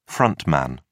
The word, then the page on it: front man: British English